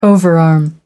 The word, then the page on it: overarm: American English